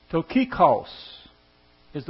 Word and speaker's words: Tychicus: In the Greek, you might notice that it's not T-Y-C-H-I-C-U-S. It's T-U-C-H-I-C-O-S. And that is Tychicus, is the way you pronounce it in the Greek.